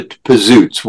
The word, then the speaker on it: Pizootz: we trademarked the name, and now, and we changed the pronunciation to Pizootz. We Americanized it.